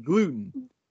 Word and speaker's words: gluten: Glutton? What do they call it? It's pronounced gluten.